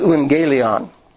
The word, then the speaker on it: euangelion: The word gospel in the Greek is euangelion. E-U-A-N-G-E-L-I-O-N. It's almost like evangelion, except the U is a V. In English, we pronounce that U a V. And so we would say evangelist. But in Greek, it was euangelist. That U, a V is a U.